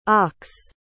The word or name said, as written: aux